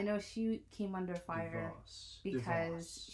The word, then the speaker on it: DeVos: But I know Betsy Dubois, is that how you say her name? – Betsy DeVoe. DeVos, whatever. I gave her a better name. – Or DeVos, not Dubois.